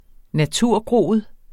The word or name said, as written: naturgroet